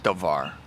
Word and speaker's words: davar: But the bar translation, actually it's spelled the bar, but it's pronounced davar.